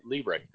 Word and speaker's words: Liebrecht: It's Jason Liebrecht. Did I pronounce that right? – You did pronounce it correctly.